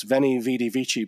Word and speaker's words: Veni, vidi, vici: Then the most famous line of Latin, and because one thing I remember from my teacher is that we pronounce the V's as W's. So it's the old 1066 and all that joke, the schoolboy howler of Caesar arrived on the beaches of Britain and announced Veni, Vidi, Vici.